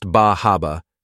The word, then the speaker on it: Bar Harbor: Bar Harbor. Often pronounced Bah Haba, this is one of the most delightful stops on the cruise.